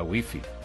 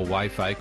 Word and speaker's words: Wi-Fi: Puedes en los ajustes fijar para que se descarguen los nuevos episodios solamente vía Wi-Fi o Wi-Fi como se pronuncia en inglés.